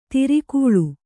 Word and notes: ಧಾರೆಗಾಳೆ: dhāregāḷe

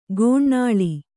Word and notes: ಗೋನಾಳಿ: gōnāḷi

